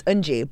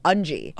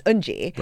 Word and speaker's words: Eunji: in Korean, it's Eunji. But in English, the way it's spelled, I always corrected people as Eunji.